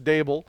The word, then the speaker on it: Daboll: D-A-V-O-L. – No, it's D-A-B-B-O-L. – Is it D-A-B? – Yeah, Dable or Dabble, whatever it is. I think you're right, Jordan. It's Dable. He's coming from a successful program in the Buffalo Bills.